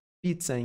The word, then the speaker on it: pizza: A pronúncia é pizza. Pizza, pizza, pizza, pizza. Não é pizza, é pizza. Tá? É pizza. Alright? Cuidado pra não falar pizza, pizza. Não é esse p, sim p, pizza.